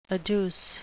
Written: adduce

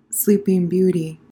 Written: Sleeping Beauty

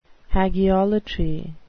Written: hagiolatry

